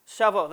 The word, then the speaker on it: Shavuot: How do you say it properly? – Shavuot. – Shavuot, there you go.